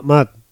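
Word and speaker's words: Mud: Muse, no sé si se pronuncia Muse o Mad. – Matt, Mad, ¿no? – sí. – Mad, vale.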